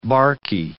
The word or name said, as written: barky